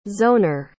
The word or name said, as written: zoner